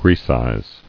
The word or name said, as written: Grecize